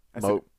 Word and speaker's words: Mowgli: Ogly. – Mowgli. – Mowgli. Mowgli. Said it wrong. – I said it wrong at first, but then I was like, oh, yeah, Mowgli.